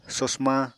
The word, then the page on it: sosma: Apatani